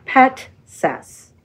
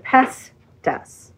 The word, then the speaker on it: Petsas: It's spelled P-E-T-S-A-S. – And it is pronounced? – Petsas. – That is exactly what I said. – You said Pestas.